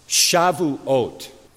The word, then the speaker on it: Shavuot: Well, in Hebrew, it is pronounced Shavuot, but it's also called not only Pentecost, also called Feast of Weeks.